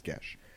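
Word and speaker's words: geas: And so, basically, it's a Gesh, or a G-E-A-S. It's pronounced Gesh.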